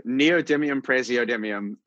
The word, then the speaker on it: neodymium praseodymium: And also, can you please pronounce NdPr, because I was having a hard time with it. – Sure. Neodymium praseodymium.